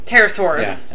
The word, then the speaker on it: Pterosaurs: Is that the Thunderbird could be and I really can't pronounce this. It is Pterosaurus. Pterosaurs? – Where's it at? – Pterosaurs? Pterosaurs? – Pterosaurs. – Yeah.